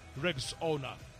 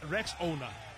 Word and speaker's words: Rexona: But it appears some people don't know how to pronounce this new name. A friend even called it Rexona. But it's called Rexona. Like Rexona. Rexona. This new name comes with a fresh new look and advanced body heat technology.